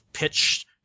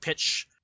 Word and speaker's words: Pitsch: So did you guys watch that commercial I sent you of the Appleton attorney, Eric, I think his name is pronounced Pitch, P-I-T-S-C-H, Pitch,